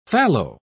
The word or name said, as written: fallow